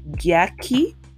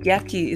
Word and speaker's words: Gyakie: But yeah, please definitely check out the song by yeah, Gyakie. Um, Gyakie is spelled,